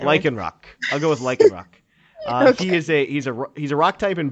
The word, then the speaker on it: Lycanroc: Is that how we're gonna pronounce it? Lycanroc, Lycanroc. – I would pronounce it like that.